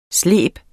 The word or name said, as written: sleben